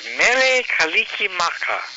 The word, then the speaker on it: Mele Kalikimaka: It goes, Mere Kalikimaka. – Oh, that's the one I wrote down last year, Kalikimaka. I still got it on my... – Mere Kalikimaka.